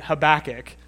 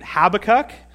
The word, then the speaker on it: Habakkuk: I listened to a number of different Hebrew scholars and pronunciation videos, and they all pronounce it Habakkuk. I'm going to do my best to do the same, but I've spent the majority of my life calling it Habakkuk.